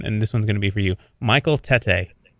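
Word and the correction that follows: Tetteh: It's pronounced Titty.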